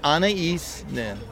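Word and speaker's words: Anaïs Nin: Now, I always pronounce Anais Nin wrong, or Anais. What? – Anais. – Anais Nin.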